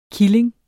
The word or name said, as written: killing